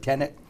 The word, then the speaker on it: Tenet: We're going to the show tonight, the movie theater, to see Tenet. Is that how to pronounce yep. – it? Tenet.